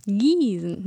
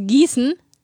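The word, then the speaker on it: Giesen: Es mit weichem S. Mit Gießen. – Gießen. Nicht das Gießen.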